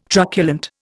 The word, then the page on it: truculent: Indian